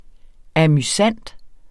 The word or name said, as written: amusant